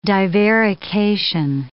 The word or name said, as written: divarication